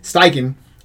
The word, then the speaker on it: Steichen: Steichen.